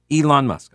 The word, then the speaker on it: Elon Musk: Pete, your pronunciation is correct. Elon Musk.